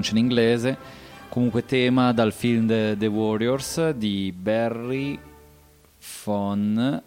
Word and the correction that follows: De Vorzon: aspettate, Barry de Vorzon.